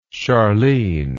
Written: Charlene

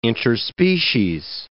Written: interspecies

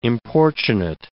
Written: importunate

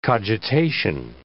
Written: cogitation

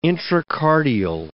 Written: intracardial

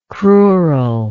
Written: crural

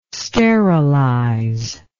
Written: sterilize

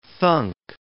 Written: thunk

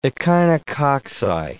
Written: echinococci

